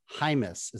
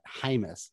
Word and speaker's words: Jamis: Well, I'll tell a little bit about Brendan Jamis, is how you actually pronounce that. Jamis. James, so he was actually... No, the E and the I switch.